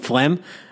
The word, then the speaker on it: phlegm: He's getting a PhD in language, but he can't even answer why the G is silent in phlegm.